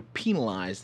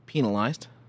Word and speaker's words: penalized: Catch being that if you don't complete your path by the end of the game, you're penalized the number of points on... – How did you say that word? – Penalized. – That's how you say it. – Like a penal colony.